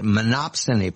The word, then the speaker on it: monopsony: However, I learned from Richard, I did not know this, that progressive economists believe that the monopsony power suppresses wages 10%.